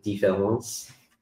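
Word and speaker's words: différance: It's a deliberate misspelling of the French word différence, which is still pronounced the same way as, so the two words différence and difference, they're both pronounced the same.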